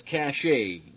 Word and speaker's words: cache: Starting off in C, it says cache. That's C-A-C-H-E. Cache, cache, depending on what part of the world you're from, how you pronounce that.